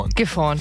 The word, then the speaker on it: Gifhorn: Gifhorn, ja. – Ja, Leute, wenn ihr Bock habt mitzuraten, gar kein Thema. Eine Runde durch den Norden, einfach anmelden auf enjoy.de. Das wäre nur geil. Gifhorn.